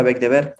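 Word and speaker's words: Beigbeder: Eh, que se pronuncia Beck de Ver,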